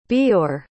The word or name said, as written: Beore